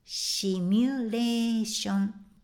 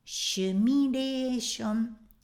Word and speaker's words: シミュレーション: シミュレーションでした英語のスペルや発音から考えれば当然です It stands to reason 当然です。でも、リサーチ、リサーチ、リサーチしてみると、やっぱり、シュミレーションというカタカナ、つまり発音をしている日本人は多いようです。